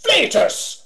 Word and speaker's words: flatus: The word is pronounced flatus, not flatus, you flatheads.